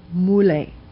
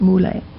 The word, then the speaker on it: Mulay: And one of the Mongol generals was named Muley, M-U-L-A-Y is the way we would spell it, Muley.